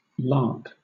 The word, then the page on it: lakh: British English